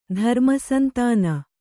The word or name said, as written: ಧರ್ಮಸಂತಾನ